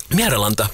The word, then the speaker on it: Miralanda: You'll have to help me with pronunciation of this next piece, Pekka Miralanda. – Miralanda, yes. You're very close.